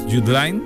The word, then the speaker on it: Judeline: Y entonces se escribe Judeline, pero es Jude Lyne, que es el nombre artístico, y que es la primera andaluza que ha estado en el top mundial de reproducciones.